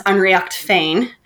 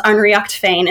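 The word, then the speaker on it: Ár nDraíocht Féin: I found the organization I'm in, ADF, which in not English, in Irish, is pronounced Unreacht Fane.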